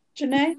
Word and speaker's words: Jhené: Jhené, yeah.